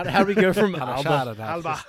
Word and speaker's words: Alba: Alba. Alba. – Alba. – Alba is Scotland. – Alba. – Alba really? Is Scotland. – A-L-B-A, pronounced A-L-B-A-H, Alba.